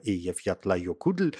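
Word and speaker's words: Eyjafjallajökull: И с ударением, ну вот я как-то больше ухожу в лайокудль, кто-то говорит Эйяфьятлайокудль. Это все не не имеет никакого такого серьезного значения в русском языке, потому что мы все равно не произносим это так, как произносят это правильно сами исландцы, так что,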